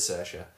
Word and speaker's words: Saoirse: Say it how it's pronounced. – Saoirse. – As it's written, rather. – Saoirse. – It makes a mockery of the English language, I think. – It really does, but it is Saoirse.